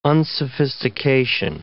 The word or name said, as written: unsophistication